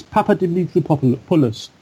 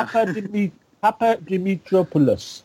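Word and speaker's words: Papadimitropoulos: Right, it's Papa Dimitri Poulos. – Wow. – Greek, yeah. – Papa Dimitri Poulos.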